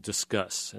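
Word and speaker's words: Disqus: The biggest comment engine is called Discuss. It's spelled kind of weird, but that's how you pronounce it.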